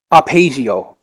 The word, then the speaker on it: Arpeggio: I was sleeping, and it's Arpeggio. Okay, that's how you say it.